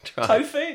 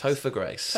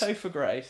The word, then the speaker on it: Topher Grace: Tofu? No. Topher? Is that correct? – Topher Grace. – Topher Grace. – I think it's short for Christopher.